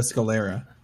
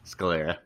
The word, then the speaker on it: Scalera: Sorry, I can't pronounce names very well. Scaleria. – Mateo Scalera. – Scalera. Thank you.